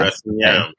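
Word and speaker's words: Bress 'n' Nyam: Am I pronouncing it right? – Yes, you are. Yes. Bress in Niam. – Bress in Niam.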